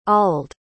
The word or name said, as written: ald